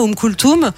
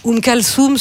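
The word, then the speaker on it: Oum Kalthoum: j'ai été biberonnée à Oum Kulthoum, Oum Kalsoum, selon comment on prononce.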